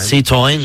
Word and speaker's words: Citroën: Citroën.